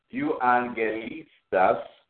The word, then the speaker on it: euangelistas: Uh, the word uh, euangelistas Euangelistas, and that's spelled E U A N G E L I S T A.